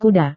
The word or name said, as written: kuda